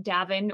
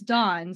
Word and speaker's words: Davvn: but everybody like will call us like Davin or Dawn. – See, and I'm, like, I even said it wrong the first time, so now I know it's Dawn.